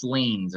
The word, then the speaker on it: liens: Tax liens, or however you say that word, L-I-E-N-S. – That's how you say it.